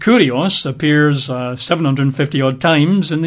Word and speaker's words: kurios: The Greek word is kurios. K-U-R-I-O-S. Kurios. K-U-R-I-O-S. I think it's pronounced kyrios in modern Greek and probably original Greek, but kurios, kyrios.